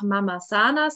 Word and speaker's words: Mamasana: Ähm, tatsächlich ist es bei uns in der Region aber so, dass viele einfach Mama Sana sagen, also quasi die Betonung auf Mama und Sana, was aber auch total in Ordnung ist, weil Sana steht ja für Gesundheit und Mama, natürlich das, was ich bin, das, was meine Kunden hauptsächlich sind, nämlich Mütter.